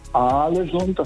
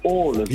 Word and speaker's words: Ålesund: Haaland, und zwei A's werden als O ausgesprochen und ein A mit einem Kringel oben drauf ist eigentlich auch eine Aussprache, ein O. Also das heißt nicht Alesund, das heißt Ålesund.